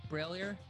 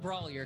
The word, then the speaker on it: Brailier: Did I say it right again, Brailier? – Yeah, you got it. Yeah, I did it. – I was like, it's not Brawlier, because I think we did that last time, and I think I got a talking to afterwards.